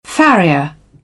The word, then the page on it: farrier: British English; əɹ